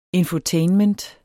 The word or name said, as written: infotainment